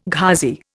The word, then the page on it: Ghazi: Indian